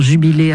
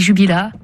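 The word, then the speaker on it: Jubilä: Moi je dis jubila, mais jubilé c'est très bien.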